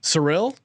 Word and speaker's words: Cyril: Cyril. – Cyril. – Cyril. – Cyril. – C-Y-R-I-L.